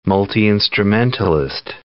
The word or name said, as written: multi-instrumentalist